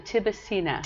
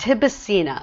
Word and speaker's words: Tibicina: How do you say it? A tribicina? Tribicina? Tribicana? – Tibicina. – Tibicina. – Tibicina.